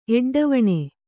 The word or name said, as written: ಎಡ್ಡವಣೆ